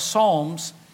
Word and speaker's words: Psalms: The P is silent, Psalms.